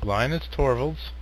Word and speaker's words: Linus Torvalds: Linus Torvalds.